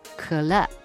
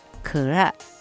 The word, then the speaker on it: 可乐: コーラ、これはクラ、クラと発音します。